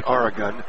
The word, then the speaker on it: Arogun: Jazzy Aragon, spelled A-R-O-G-U-N, pronounced Aragon, 6'7", senior pivot man, 7 points a game, 8 rebounds, senior.